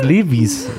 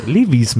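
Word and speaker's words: Levi's: Eigentlich, eigentlich ist es Levis. Levis müsste man es richtig aussprechen.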